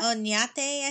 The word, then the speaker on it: Oñate: No, it's like, it's O-N with the tilde A-T-E. Yeah. Oñate, I think, is how the pronunciation Juan.